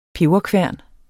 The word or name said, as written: peberkværn